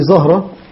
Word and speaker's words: زهرة: لكن لا، زهرة تنطق هكذا بفتح الزاي، كأنه يحضرني أن تكون بالضم، يعني بماذا جزمت؟ على أي أساس جزمت؟ فكانوا يضبطونها زهرة بالفتح.